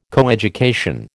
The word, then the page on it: coeducation: American English